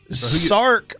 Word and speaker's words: Sark: Sark rhymes with bark.